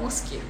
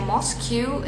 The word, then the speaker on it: mosque: Mosque atau mosque? so, the correct one is the correct one is the first one. Mosque. Ya, jadi kalau kamu biasanya pronounce yang Moscow, it means you need to correct it.